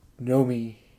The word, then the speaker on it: Naomi: Because, yeah, it's Naomi, but in Japanese it's like Nomi.